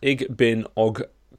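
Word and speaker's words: Igbinoghene: Yeah, his name is pronounced Igbinogene.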